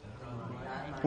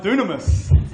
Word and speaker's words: Dunamis: When you first see that word, doesn't that look like dynamite? All right, go to the next slide there. All right, so it's not pronounced that way. Look how it's pronounced.